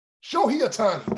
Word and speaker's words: Shohei Ohtani: Shohei Ohtani, thank you.